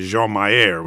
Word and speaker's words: Jean Mayer: It's important to give the credit to Jean Mayer, which is how he pronounced his name,